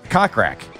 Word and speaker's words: Kokrak: It's K-O-K-R-A-C-R-R-A-K. K-O-K-R-A-K. – K-O-K. – Kokrak. – Kokrak. – R-A-K? – Yeah, I guess it's Kokrak. – I got one of those in my truck. – Kokrak?